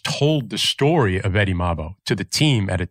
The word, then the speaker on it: Mabo: And his great uncle, Eddie Mabo, I don't know if I'm saying that right. – Mabo, I think, yeah.